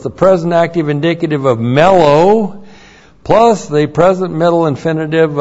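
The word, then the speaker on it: mellow (μέλλω): and then mellow, M-E-L-L, long O, mellow.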